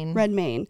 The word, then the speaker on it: Redmayne: How do you pronounce his last name? – Redmayne.